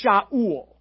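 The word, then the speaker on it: Shaul (שאול): In Hebrew, you would say the name Saul, it'd be pronounced Shaul.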